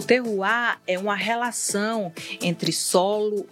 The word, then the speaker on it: terroir: Mas seguindo aqui na nossa programação, o que, que é o terroir? É assim que fala? Tô falando certo? Tá falando certo.